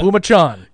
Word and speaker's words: Pumachon: Just spell it phonetically for me on my notepad to your spotter. – In big giant letters. – Yeah. P-U-M-A-C-H-O-N. Yeah. Pumachan. Yeah. There it is. Pumachan.